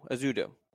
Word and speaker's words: Ezeudu: How do you Ezeudu. – Properly pronounce that? – Ezeudu. Ezeudu. – Ezeudu. Okay, got it.